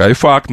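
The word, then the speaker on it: iPhuck: Читается как «iFuck», но это омофон. И не имеет отношения к ругательству.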